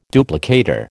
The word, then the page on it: duplicator: American English